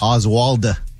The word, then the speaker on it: Oswald: Is it Oswald or Oswalt? – Oswald. – Wald. – Oswald.